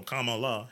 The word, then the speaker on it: Kamala: So it's comma, you know, like I'm trying to say apostrophe, but punctuation. So Kamala Harris.